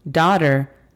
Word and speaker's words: daughter: Daughter significa hija. La pronunciación fonética de esta es D-A-D-R. Daughter.